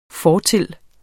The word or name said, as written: fortil